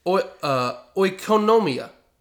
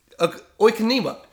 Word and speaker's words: oikonomia: is oikonomia. Now, that was mixed with a little bit of Japanese, I do believe. Oikonima. It's O-I-K-O-N-O-M-I-A.